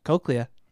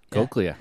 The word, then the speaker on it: cochlea: Yeah, I just said that right. Cochlea. – Cochlea.